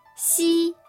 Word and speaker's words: xi: One is it's very different than the X in English.